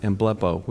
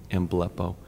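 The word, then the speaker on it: emblepo (ἐμβλέπω): It's based on one Greek word, that's pronounced emblepo, which isn't the prettiest sounding word, emblepo.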